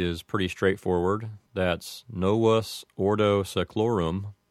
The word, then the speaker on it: novus ordo seclorum: I can't really think of any other way that that would be pronounced. I suppose that if someone said it with an Americanized pronunciation, they might change the sound of the V in novus, and they might say novus, but that's really the only difference I could think of.